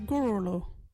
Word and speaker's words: gurulhu: Wajiri word is gorolo. What is a gorolo? Gorolo is a black swan. You can use gorolo in this sentence. Look there. See the gorolo? Gorolo is spelt G-U-R-U-L-H-U.